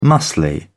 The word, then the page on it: muscled: British English